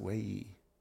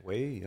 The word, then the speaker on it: Wei Yi: And India, man, I remember, I'm sorry if I mispronounce it, Wei Yi. – No, from China. – That's good. Wei Yi, yeah.